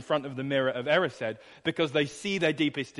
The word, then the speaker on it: Erised: Did I say that right? The Mirror of Erised? Yeah, I'm getting a couple nods. Good. Okay, said it right.